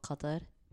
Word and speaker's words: Qatar: So we lived together in a small little country called Qatar.